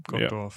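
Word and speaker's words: Gottorf: Spricht man das so aus? Gottdorf? – Ja, Gottdorf. Ja.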